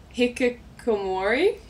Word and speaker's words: hikikomori: Your shirts raise awareness for correct me if I'm pronouncing this wrong, but the hikikomori. – Mm-hmm. – Okay, awesome. Yeah. Gold star to me.